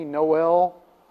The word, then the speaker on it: Noel: And maybe because people call me Noel, Noah. People don't know how to say my name.